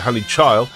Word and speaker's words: Chile: The Poets and Honey Chili. Sorry, they call it Honey Chile, but we call it Honey Chili in the way it's spelled.